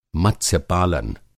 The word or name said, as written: मत्स्य-पालन